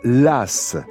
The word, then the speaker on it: Laàs: Il y a 130 euh, habitants environ à Las.